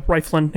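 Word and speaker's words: Rieflin: So instead of rambling on about them, I'm just going to say Rest in peace, Bill Riflin. Is that how you say his name? – I believe so. Riflin, yeah.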